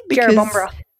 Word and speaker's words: Jerrabomberra: Did I pronounce it correctly? – Jerrabomberra.